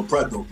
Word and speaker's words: Preto: I'm putting Preto in the street, but I'll be having to tell him that because I get it how it's spelled and all that, but it's Preto.